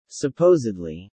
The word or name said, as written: supposedly